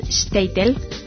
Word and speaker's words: Shtetl: Y él nos puntualiza que en Europa Oriental, en Yiddish, el modo de pronunciar correcto es Shtetl.